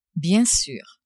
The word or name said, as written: bien sur